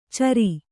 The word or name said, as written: ಚರಿ